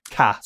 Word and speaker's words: Ka: Or is there not an official pronunciation for the Ka stroke KA? – Well, again, there never was. Technically, it's KA, because it's they're two capital letters and KA, but again, it's, well, actually, that's the ultimate one, isn't it, that we've stumbled on there.